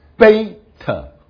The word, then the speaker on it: beta: 第一个是子音是 B 的口型，所以是 beta 啊。那你可以在旁边写好像 boy 哈 B 的发音啊。